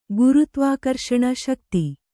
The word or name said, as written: ಶಕ್ತಿ